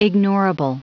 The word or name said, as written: ignorable